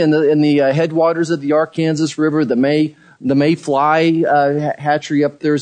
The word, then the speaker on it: Arkansas: Now, the Arkansas River is spelled like Arkansas, but we pronounce it Arkansas River as it goes out of Colorado through Kansas.